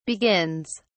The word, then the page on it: begins: British English